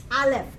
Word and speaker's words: Aleph: Aleph. Can you say Aleph? – Aleph. – Actually, a silent letter. Okay? You don't pronounce it.